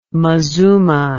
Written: mazuma

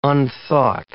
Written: unthought

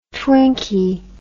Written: twinkie

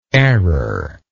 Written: errorlessly

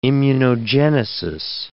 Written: immunogenesis

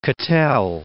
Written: Cattell

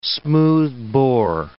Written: smoothbore

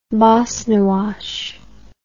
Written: bosnywash

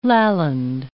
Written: Lalland

